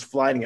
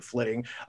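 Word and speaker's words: flyting: I want to say it is flitting. I thought it was flying at first, but I believe they pronounced it flitting, which is the like Viking rap battle that you can do within the game.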